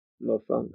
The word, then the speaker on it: mofongo: M-O-F-O-N-G-O. – Mofongo.